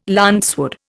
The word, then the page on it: lancewood: Indian